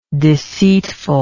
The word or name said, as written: deceitfulness